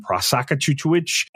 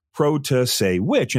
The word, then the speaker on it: Protasiewicz: said, how do you say Protasiewicz? And they would show it and everyone would get it wrong, Prosakachuchuich. And then eventually it was, you know, Protasiewicz, and everybody realized how to pronounce it, maybe not spell it.